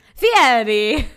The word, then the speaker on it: Fieri: How do you say his last name? Fieri? – Fieri.